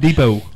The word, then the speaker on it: Depot: Home Depot has a T at the end there, and you don't even pronounce it. We want to save the environment. – Depot.